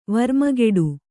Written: ವರ್ಮಗೆಡು

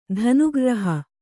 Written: ಧನುಗ್ರ್ರಹ

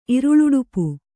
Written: ಇರುಳುಡುಪು

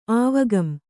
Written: ಆವಗಂ

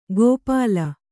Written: ಗೋಪಾಲ

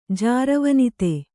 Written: ಜಾರವನಿತೆ

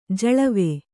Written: ಜಳವೆ

